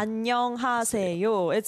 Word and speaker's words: 안녕하세요: 안녕하세요. It's a 5-syllable word.